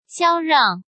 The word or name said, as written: Xiao Rang